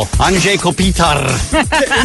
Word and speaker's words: Anže Kopitar: Anže Kopitar.